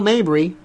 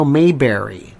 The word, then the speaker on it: Mabry: Dale Mabry, big stretch in Tampa. Also, people pronounce it Dale Mayberry. They mispronounce it like Bradington.